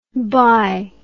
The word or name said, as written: bhai